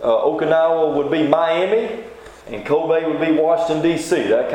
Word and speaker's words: Kobe: They pronounce it Kobe there. Kobe, Japan.